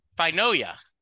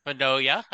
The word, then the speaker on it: Finoia: It's Mike Finoia. – Almost. – Almost. – 98%. Give you an A minus, but yeah, great effort. It's Finoia. – Finoia, okay.